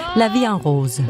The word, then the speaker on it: La Vie en Rose: Edith Piaf, La Vie en Rose. – Ja. – Som jeg overhovedet ikke udtalte rigtigt. – Nej. – Hvordan skal det siges? – La Vie en Rose.